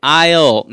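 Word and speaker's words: aisle: 10번에 I'll 나왔는데 I'll 뜻은 S가 묵음이에요. I'll로 읽으면 안 됩니다.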